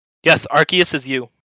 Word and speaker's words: Arceus: It's Arceus.